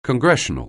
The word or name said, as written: Congressional